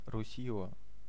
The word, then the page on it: Russia: /ˈɹʌ.ʃə/